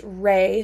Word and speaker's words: rei: And then a really good way of like knowing how to pronounce it is just rei. So like picture like a ray of sun, a ray of light, of energy, and then ki, like a key to unlock something.